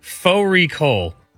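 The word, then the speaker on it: Fårikål: Faux recall, which is a mutton and cabbage. It's spelt like far recall.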